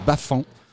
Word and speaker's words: Bafang: On prononce pas le G, ça se dit Bafang.